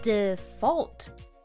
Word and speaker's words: default: 発音に要注意 D、 F、 L、 T これらを短くつなげて発音してみましょう Default こんな感じで Default Yes!